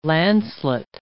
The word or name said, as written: lancelet